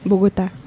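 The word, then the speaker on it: Bogotá: Ну, это, это же, ну, крупный достаточно, да, А как э, произносят на, в Колумбии? – Богота. – А. – Богота, скорее всего.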